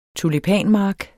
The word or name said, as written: tulipanmark